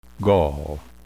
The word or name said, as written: gall